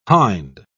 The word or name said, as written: hind